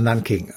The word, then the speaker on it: Nanking: Also man kann es nachgucken, NAN und dann King, also Nanking.